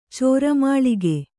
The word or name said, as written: ಚೋರಮಾಳಿಗೆ